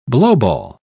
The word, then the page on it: blowball: British English